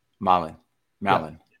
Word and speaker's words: Malin: Why does Ben, and how do I say your last name, Ben? I'm sorry I didn't ask that. – Malin. – Malin, the English way.